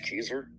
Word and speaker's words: Kezer: My name's Josh Keyser. My last name is spelled K-E-Z-E-R.